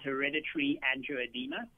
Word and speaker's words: hereditary angioedema: I hope I'm pronouncing that correctly. – Yeah, that's correct. It's pronounced exactly that, hereditary angioedema.